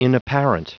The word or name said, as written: inapparent